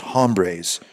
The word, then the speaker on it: Hombres: you've decided you felt safe enough to stop pronouncing a hard H on your own best-selling cigar line of Dos Hombres.